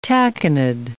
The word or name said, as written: tachinid